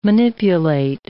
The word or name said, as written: manipulate